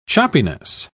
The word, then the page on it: choppiness: British English